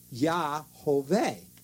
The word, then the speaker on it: Yahweh: Now, if you put a certain combination of vowels to the four letters, the Yod, Hey, Vav, Hey, you can pronounce it Yahweh.